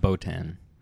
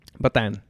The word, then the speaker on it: Bataan: I was thinking about Botan. – Botan. Mm-hmm. Okay. No, it's Bataan? – Bataan. Yeah, it's I'm pretty sure that's how you say it.